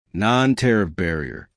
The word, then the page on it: non tariff barrier: American English